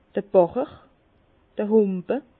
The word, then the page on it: d'r Bàcher: Bas-Rhin; Herrlisheim